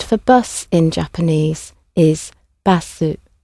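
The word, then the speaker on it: バス: So obviously it comes from the English originally and has had the extra vowel sound added to the end and a more of a softer a sound at the beginning, basu.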